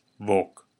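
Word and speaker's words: voq: The verb today is vok. I'll say it again. Vok. We spell that V-O little q, and to most English speakers, that little q sounds like a K. But not just any K sound. No, it's the K you make at the back of the mouth.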